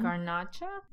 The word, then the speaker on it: Garnacha: Garnacha.